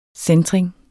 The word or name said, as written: centring